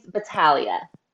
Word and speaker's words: Battaglia: So the married name's Battaglia. The G is silent.